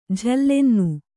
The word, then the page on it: ಝಲ್ಲೆನ್ನು: jhallennu